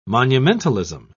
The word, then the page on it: monumentalism: British English